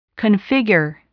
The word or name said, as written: configure